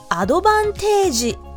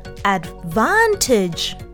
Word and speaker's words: advantage: アドバンテージではなく advantage という発音を意識しながら言ってみてくださいそれではみんなで Let's repeat after Nikki 私の方が彼より有利だ I have an advantage over him Sounds great.